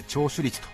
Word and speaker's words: 聴取率: ラジオでは聞き取ると書いて聴取率と読みます